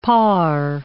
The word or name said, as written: par